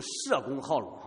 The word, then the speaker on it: 叶公好龙: 那中国有一个成语叫做叶公好龙，也可以读成社公好龙哈，树叶的叶，他以前读姓的时候可能是念社，叫社公好龙啊。